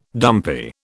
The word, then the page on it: dumpy: British English